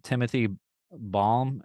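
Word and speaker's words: Timothy Balme: Timothy Baum, is that how you say his name? Yeah, he's great.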